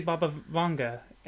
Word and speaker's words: Vanga: Typically in other languages, the A is pronounced one way. There's no variation like in the English language. – So pronounce it for me. – I'd say Baba Vanga.